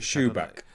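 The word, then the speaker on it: Shubik: The Irene, is it Shubrick? – Shubak, yeah.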